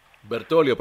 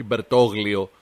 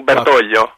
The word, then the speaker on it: Μπερτόλιο: Μπερτόλιο προφέρεται στο μεταξύ, όχι Μπερτόγλιο. – Όχι, Παναγία μου, Μπερτόλιο.